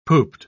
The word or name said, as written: pooped